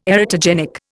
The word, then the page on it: erotogenic: Indian